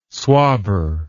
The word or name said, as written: swabber